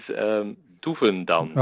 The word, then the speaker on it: Doevendans: How would you say that in Dutch? – Okay, I will. The first name pronunciation was perfect. – All right, I'll take it. – And the surname is Doevendans.